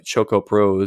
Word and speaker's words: Choco Pro: One of the Choco Pro? – I think Choco, like chocolate. – Choco Pro.